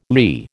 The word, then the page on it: lee: American English